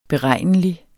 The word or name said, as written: beregnelig